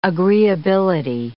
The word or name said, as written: agreeability